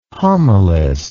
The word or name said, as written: homilist